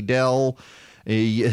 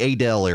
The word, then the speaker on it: Adel: Uh, I will tell you if you are listening uh, down in Quitman, Valdosta, Adel, Eh, that, that's another one. Uh, the Adel area. Speaking of, of of pronunciations here, as opposed to I heard had had somebody refer to it to me as Adele. Heard you were on the radio in Adele. I was like, you mean Adel?